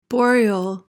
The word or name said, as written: boreal